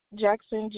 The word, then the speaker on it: Jackson: Jackson, J-A-C-K-S-O-N.